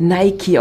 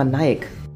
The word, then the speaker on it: Nike: Ja, das Stirnband ist wirklich ganz cool, aber das wird Nike ausgesprochen. Aber passiert ja den meisten, die sagen immer Nike.